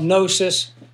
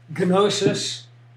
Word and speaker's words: gnosis: You know, in the Greek, they don't have silent letters, so we call it gnosis. The Greek would say gnosis.